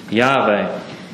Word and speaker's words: Yahweh: And while the vowel sounds are uncertain, it's commonly held that it could be best to pronounce his name Yahweh.